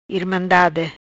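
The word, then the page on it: Irmandade: Patuá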